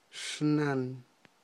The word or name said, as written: xnan